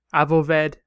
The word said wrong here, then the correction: Avowed: as I mispronounced it, from Obsidian. This, the first-person RPG, Avowed.